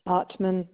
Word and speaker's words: Ottman: First name is Mary, M-A-R-Y. – Can I have your last name, please? – Ottman Bass, which is spelled O-T-T, M as in Mary, A, N as in Nancy.